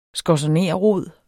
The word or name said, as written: skorzonerrod